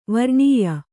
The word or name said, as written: ವರ್ಣೀಯ